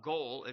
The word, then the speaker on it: goel: Now, the kinsman, in Hebrew, by the way, that's the word goel, it's spelled in the English, G-O-E-L.